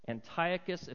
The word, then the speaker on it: Antiochus: But, and I always mess this guy's name up, Antioch, there's one more syllable, Antiochus Epiphanes desecrated the temple.